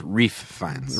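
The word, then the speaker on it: Ralph Fiennes: It's pronounced Reef Fiennes.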